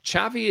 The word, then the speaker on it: Xavi: No, it's in Catalan. It's not, it's the X is a cha, isn't it? – Yeah. – So it's not a za, you know. – Xavi Hernandez.